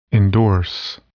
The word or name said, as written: endorse